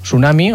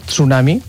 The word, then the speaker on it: tsunami: En tsunami tenim dues opcions. Podem fer tsunami o tsunami. Jo crec que és més còmode sense fer la T inicial.